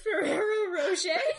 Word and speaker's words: Ferrero Rocher: Are you trying to say Ferrero Rocher? – Yes.